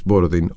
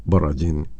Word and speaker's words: Borodin: De Piotr Ilich Tchaikovsky se ha escuchado Cuarteto de Cuerdas en si bemol mayor por el Cuarteto de Cuerdas Borodin o Borodin en su pronunciación original.